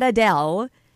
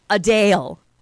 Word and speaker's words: Adele: It's not Adele. It's Adele. Like, so, really quick, and then Dale. Like, a long emphasis on not Dell, Dale.